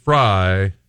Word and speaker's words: Frey: Kylie French Fry is the Instagram, and I did a Facebook Live every Friday. – Friday. – I know. It looks like Frey, but it's pronounced Fry.